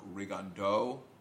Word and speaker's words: Rigondeaux: It's a French last name, and it's pronounced Rigondeaux, but that's apparently how he pronounces it. It's I guess, his French name with a Cuban Spanish accent.